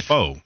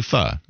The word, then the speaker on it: pho: Oh, it's pho, not pho. – I thought it was pho for a long time. It's actually pho.